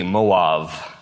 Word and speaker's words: Moav: She lives in the land of Moab, or actually in Hebrew it's pronounced with a V, Moav.